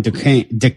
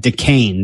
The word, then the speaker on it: Duquesne: And I don't even know how to say the first name on their schedule there. DeQuisney, or whatever. Oh, they're playing Duquesne. Jesus, fuck, I can't say it. Duquesne they open up against, Duquesne.